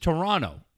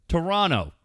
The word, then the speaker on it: Toronto: you don't pronounce the second T. It's just Toronto. – Say it again. – Toronto.